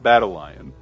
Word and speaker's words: Battalion: As the pedant here, it's pronounced Battle Lion. – Hmm. – That's how it's pronounced.